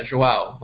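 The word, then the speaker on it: João: Yeah, Joao. Like, wow, with a J.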